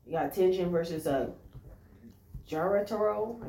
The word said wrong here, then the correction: Jotaro: I think that's how you say it. – Yeah, Jotaro, yeah.